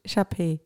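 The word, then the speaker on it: Shar-Pei: Sharpei.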